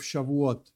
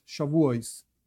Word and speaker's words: Shavuot: First of all, what is this holiday of Shavuot, Shavuos? My Ashkenazic pronunciation and so on makes it a little bit different, but whatever, it's the same idea.